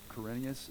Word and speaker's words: Quirinius: Let me just tell you, it's really hard to pronounce that term, Quirinius.